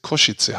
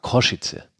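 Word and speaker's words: Košice: Ganz Bratislava war eigentlich zwei Wochen Feierfest ähm, und jetzt kommt noch Kosice mit hinzu. Ähm, richtig ausgesprochen, wie du es sagst. Übrigens, Koschice habe ich gehört. Koschice. Koschice. Dann werde ich das auch versuchen, so in Zukunft zu sagen.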